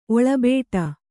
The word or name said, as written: ಒಳಬಟ್ಟೆ